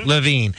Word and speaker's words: Levine: Am I saying that right? I always get his last name. – Levine. He goes by Levine.